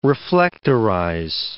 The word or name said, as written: reflectorize